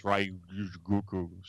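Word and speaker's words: Ryōgoku: I can't never pronounce Ryugoku, so I call it Sumo Hall. – Ryugoku. – Thank you.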